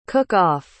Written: cook-off

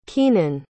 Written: kienan

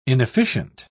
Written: inefficient

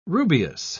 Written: rubious

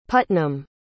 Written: putnam

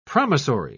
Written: promissory

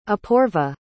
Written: apoorva

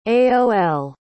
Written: aol

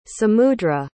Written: samudra

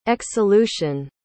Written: exsolution

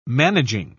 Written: managing

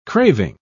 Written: craving

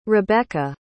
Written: rebeca

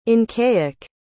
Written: incaic

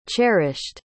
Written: cherished

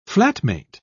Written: flatmate

